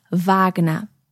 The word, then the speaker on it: Wagner: Let's just run through the correct German pronunciation of those composers' names again. Johann Sebastian Bach, Georg Friedrich Händel, Richard Wagner, Robert Schumann, Joseph Haydn and Wolfgang Amadeus Mozart.